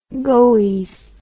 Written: goies

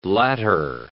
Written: latter

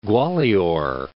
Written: Gwalior

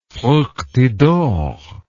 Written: fructidor

